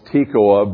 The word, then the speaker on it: Tekoa: He actually, I think in Hebrew the name would be pronounced Tekoa, but it was kind of nice for us to say Amos was the prophet from Toccoa.